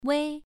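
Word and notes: wei: tone 1

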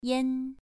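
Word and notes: yan: tone 2

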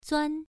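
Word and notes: zuan: tone 1

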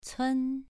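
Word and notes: cun: tone 2

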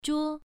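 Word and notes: zhuo: tone 1

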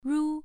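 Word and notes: ru: tone 1

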